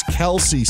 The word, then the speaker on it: Kelce: What, that it went from Travis Kelce to Travis Kelce? – Kelce. It's been Kelce, and then at some point they're like, everyone keeps mispronouncing it, so we'll just go with Kelce.